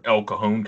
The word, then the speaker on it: El Cajon: I'm going to mispronounce it, but El Cajon, California, I would think is the way you say that. We're going to go with that if it's not.